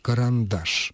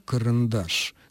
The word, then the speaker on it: карандаш: И, наконец, полный, полновесный, полноценный «а» мы слышим только под ударением между двумя твердыми согласными – «карандаш». Соответственно, если мы произносим это слово с сильной редукцией в обоих предударных слогах «карандаш», «карандаш», то это есть орфоэпическая ошибка.